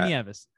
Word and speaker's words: Nieves: I've got a lot of people mispronounce it like knives and Nieves, but you got it. Victor Nieves.